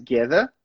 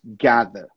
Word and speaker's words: gather: Sabe a pronúncia de gather? Muda entre Reino Unido e Estados Unidos? Muda na vogal ali. É, americanos vão falar mais gather. Gather. E aí, britânicos, gather.